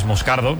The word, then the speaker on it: Moscardo: Como bien ha pronunciado Unai, es Moscardo, brasileño, sí.